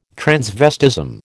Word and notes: transvestism: American English

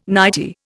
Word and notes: nightie: Indian

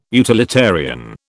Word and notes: utilitarian: British English

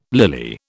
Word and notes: lily: British English

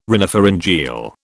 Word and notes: rhinopharyngeal: British English

